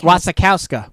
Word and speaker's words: Wasikowska: I will say this, is Mia, I will butcher her name every single time. Mia was... – Wasikowski. – Wasikowski. – Wasikowska. Wazowski. She's Mike Wazowski. – I'll go with that. That's, Wasikowska.